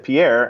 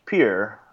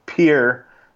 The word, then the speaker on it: Pierre: and said it's actually pronounced Pierre, and so they put that in the soundtrack itself. So there you go. Not that you were wondering, but it is pronounced Pierre, South Dakota, and all the South Dakotans rejoiced.